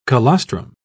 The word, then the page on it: colostrum: British English